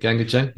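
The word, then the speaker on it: GANGgajang: And so I hear these really diverse sounds like The Church, The Crawl, Hoodoo Gurus, Gangajang? Am I pronouncing that? – Gangajang, yeah.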